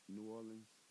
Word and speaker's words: New Orleans: Say it right. New Orleans. – I went to New Orleans. – Only out of towners call it that shit.